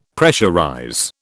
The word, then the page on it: pressurize: British English